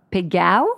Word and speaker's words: Pagot: Yes, you have to pronounce Pagot. – Pagot. – If so you want to speak in Provençal. – Good thing I asked you. – And if you want to speak in French, you say Pagot.